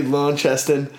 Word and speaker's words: Launceston: Yeah, you said that perfectly. So it's definitely Launceston.